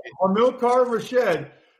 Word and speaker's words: Hamilcar Rashed: Is it Hamilcar Rashed? How do you say his name? – You got it correct. You got it correct. – Hamilcar Rashed, the linebacker from Oregon State.